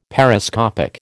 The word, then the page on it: Periscopic: American English